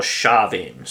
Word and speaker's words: Samhain: It's spelt Samhain, it's actually pronounced Samhain or Shavin, so I'm just going to call it Shavin.